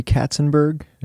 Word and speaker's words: Katzenberg: This reminds me of, I was recently listening to an interview with Jeffrey Katzenberg, I get his name right? – Yeah, this is the...